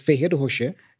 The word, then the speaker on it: Ferrero Rocher: I like that they mention another popular sweet here in Brazil, Ferrero Rocher, you know, to pronounce more in English, but, you know, in Portuguese, it's Ferreiro Rocher, yeah.